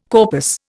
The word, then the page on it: corpus: Indian